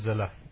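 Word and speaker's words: Zille: It's Helen Zeller. – Helen Zeller, G.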